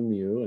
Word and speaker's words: Mu: Am I saying it right? Is it? – Yeah, that's correct. It's the Lost Continent of Mu, and it's Edward Churchward's.